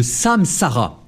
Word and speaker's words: samsara: On appelle ça le samsara, comme ça se prononce, S-A-M-S-A-R-A.